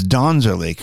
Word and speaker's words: Donzerly: She's the author of Donzerly, and it's Donzerly, correct? – Yes.